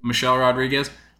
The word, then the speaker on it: Michelle Rodriguez: Is it Michelle Rodriguez? I always get her name wrong. – Yeah, you got it. – Michelle Rodriguez.